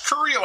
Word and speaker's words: Correia: But I'm saying it right, aren't I, Larry? – Yes, Correia like the country once it hit Ellis Island.